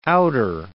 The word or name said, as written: outer